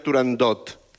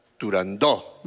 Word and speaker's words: Turandot: No se dice, no se debe pronunciar Turandot. – Ah, no, no, Turandó. – La ópera se titula Turandó.